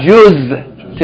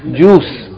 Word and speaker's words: juz: No, I read my juz today. Juz means a part. Yani, like a 30th of the Quran. Juz'un. It's not pronounced juice.